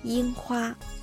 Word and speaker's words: 樱花: 樱花。 – はい、樱、ちょっと高めに出るのがコツですね。